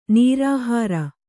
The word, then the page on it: ನಿರಾಹಾರ: nirāhāra